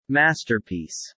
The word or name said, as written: masterpiece